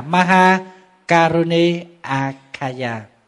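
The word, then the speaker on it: Mahākāruṇikāya: Câu thứ 6, Ma Ha Kaloni Kaya, thì nguyên văn chữ Phạn đọc là Maha Karuni Akaya.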